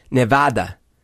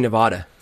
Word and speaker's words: Nevada: Well, it's Nevada. It's always been Nevada. – Nevada. – People in Nevada, Nevada pronounce it Nevada. – Nevada. But it's not Nevada. It's Nevada.